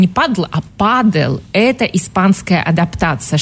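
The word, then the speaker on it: pádel: Так вот, дело в том, что испанский язык адаптировал английское слово падл под свои фонетические правила. И так появилось слово «падл» с ударением на «а» – официальное название нового вида спорта.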